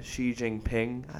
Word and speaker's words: Xi Jinping: Ping, Xi Jinping.